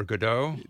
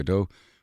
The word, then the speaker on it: Godot: But Americans tend to call it Waiting for Godot. Brits and Irish people call it Waiting for Godot or Godot.